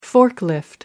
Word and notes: forklift: British English

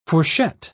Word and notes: fourchette: British English

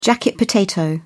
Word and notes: jacket potato: British English

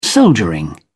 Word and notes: soldiering: British English

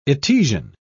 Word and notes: etesian: British English

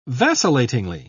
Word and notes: vacillatingly: British English

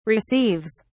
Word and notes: receives: British English

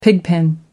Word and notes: pigpen: British English

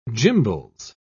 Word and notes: gimbals: British English

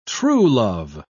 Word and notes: truelove: British English

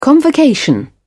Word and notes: convocation: British English

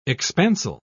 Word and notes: expansile: British English

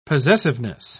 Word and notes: possessiveness: British English

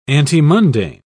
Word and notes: antemundane: British English

